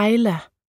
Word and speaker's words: Isla: De fleste kalder mig Isla, men det udtales Isla, ligesom det engelske ord for ø, island, bare uden nd.